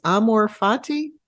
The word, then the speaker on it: amor fati: And I don't know if I'm going to pronounce this correctly, the Latin phrase amor fati. – Perfect.